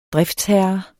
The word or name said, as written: driftsherre